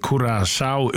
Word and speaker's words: Curaçao: Och, ja, och vi kommer att säga att landanavnet Curaçao uttalas Cura, K-U-R-A-S-A-U av vårskan Curaçao.